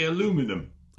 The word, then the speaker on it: aluminium: How do you say aluminium? – Aluminium? Like anybody else? – No, you don't. You say aluminum.